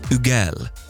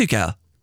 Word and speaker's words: Hugel: Is it like Hugel? Or is it... It is Hugel. Check me out. Hugel. So the H is silent.